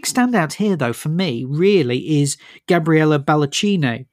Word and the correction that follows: Baldacchino: Sorry. Pronounce that wrong. Baldacchino. – Baldacchino.